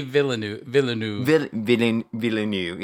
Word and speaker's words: Denis Villeneuve: Denis Villeneuve, yes.